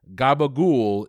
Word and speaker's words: capicola: A staple in mob movies, gabagool is one pronunciation of capicola, a type of Italian what? – Meat?